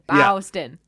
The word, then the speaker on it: Boston: B-A-W-S-T-O-N. Boston.